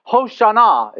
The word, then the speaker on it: Hosanna: And they're saying, Hosanna is the way you would properly pronounce it.